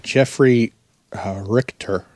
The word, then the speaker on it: Jeffrey Richter: It's by Jeffrey Richter. – Yes. – I hope I pronounced that correct. – Yep.